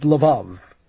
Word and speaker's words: Lvov: Swede Lvov. It rhymes with the love.